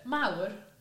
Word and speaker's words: Mawr: We've also got the Merth and Moore pudding race. – Merth and what? – Moore. How do you say it? – Mawr. – Mawr. – It's Welsh for big. – That's why I messed up.